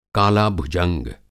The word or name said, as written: काला-भुजंग